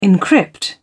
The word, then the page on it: encrypt: British English